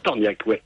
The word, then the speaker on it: Tornjak: Des torniacs, ah oui. Ça s'écrit T-O-R-N-J-A-K.